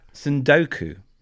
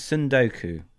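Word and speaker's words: tsundoku: It's got a T at the beginning, but I'm assuming it's a silent T. So I'm going to go with like tsunami. – Yeah. So I think it's Sundoku, which is a really great word.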